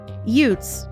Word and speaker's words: Utes: Utes, to be clear, is the local band of indigenous people, not how Vinnie Gambini pronounces youths in My Cousin Vinny.